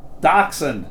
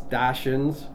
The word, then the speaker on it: Dachshund: It's derived of the German words Dachs for badger and Hund for dog. English speakers tend to butcher the pronunciation, calling these dogs Dachshunds or Dachshounds. You can either say Dachshund or Dachshund.